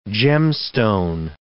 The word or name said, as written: gemstone